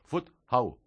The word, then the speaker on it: Fuzhou: a ver si lo pronuncio bien, Fuzhou. – Perfecto, oigo. Perfecto.